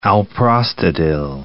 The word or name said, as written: alprostadil